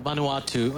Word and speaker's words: Vanuatu: Well, I said it wrong, but I said, yeah. – But I knew I'll say it the right from now on. Vanuatu.